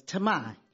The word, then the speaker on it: Tahmi: T-A-H-M-I, and found out that the proper pronunciation was Tammy.